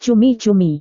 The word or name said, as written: cumi-cumi